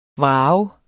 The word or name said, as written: vrage